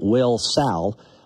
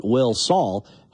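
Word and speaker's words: Wilsall: I said, hey, Clint, I always pronounce your town Will Sal. I've heard some people call it Will Saul. How do you pronounce it? And he told me exactly what Nels Swandle told me several years ago. He says, well, the town was named after Will and Sally.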